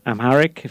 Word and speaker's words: Amharic: One of the interesting things about it is it delivers teaching in an Ethiopian language, Amharic, if I'm saying it right, and yes.